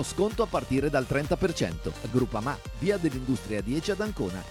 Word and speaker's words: Groupama: DJ Biancorosso Groupama, ah no, si pronuncia Groupama, di Paolo Felicetti.